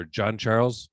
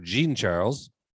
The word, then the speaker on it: Jean Charles: Shemar Jean Charles or John Charles pronounces his name Shemar Jean Charles.